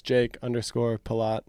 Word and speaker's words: Pelot: So Instagram, I think actually all my social medias are just Jake_Pelot.